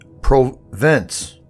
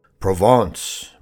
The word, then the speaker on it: Provence: Print, emps, and Provence, I said, making the words out the best I could. Provence, my father said. That's how they say it.